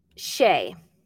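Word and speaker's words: Hsieh: We are back with Katherine Sayre, the co author of Happy at Any Cost, the biography of Tony Say. Uh, Catherine, am I pronouncing it right? I'm, I keep on. Uh, Shay. Shay, whoops.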